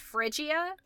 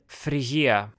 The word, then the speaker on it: Phrygia: I think in English it's pronounced Phrygia.